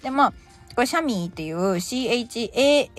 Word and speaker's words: chamois: こいつ、あの、英語だとですね、まあ、発音的にシャミーって言われるらしいんですけど、スペル全然違うんですね。